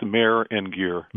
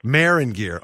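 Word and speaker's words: Meagher & Geer: He's an estate planning attorney with Mager Gear. Did I say that correctly? – You didn't. It's Mayor and Gear. – Mayor and Gear.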